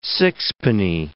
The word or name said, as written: sixpenny